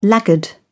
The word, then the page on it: laggard: British English